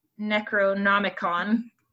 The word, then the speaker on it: Necronomicon: The Book of the Dead, discovered in a cabin in Sam Raimi's Evil Dead films, is based on Lovecraft's, Necronomicon.